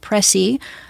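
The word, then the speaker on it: précis: P-R-E-C-I-S, with an accent on the it looks like precis, but it's preci.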